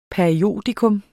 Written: periodikum

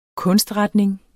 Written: kunstretning

